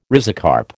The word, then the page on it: rhizocarp: American English